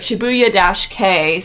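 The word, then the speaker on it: Shibuya-kei: So it's S-H-I-B-U-Y-A and then K-E-I. So it's like Shibuya-K, so Shibuya style, that's what it means.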